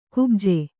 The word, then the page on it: ಕುಬ್ಜೆ: kubje; Kannada